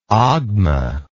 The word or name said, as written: ogma